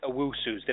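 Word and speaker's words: Owusu: 53 Chris Owusus.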